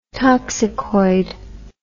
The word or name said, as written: toxicoid